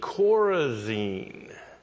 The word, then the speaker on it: Chorazin: It's spelled C-H-O-R-A-Z-I-N, Chorazin.